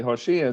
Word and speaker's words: Hosea: It says Hosea, and this is how you say Hosea. It's a ha, and this is a vav with a dot on the top for all my Hebrew students. That translates into an o.